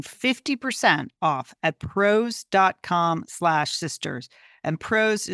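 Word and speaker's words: Prose: Couldn't be happier, PROS.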